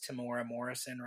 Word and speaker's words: Temuera Morrison: There is an interview that came out recently with Temuera Morrison. Did I say that correctly? It is Temuera Morrison, right? – You hit it right out of the park.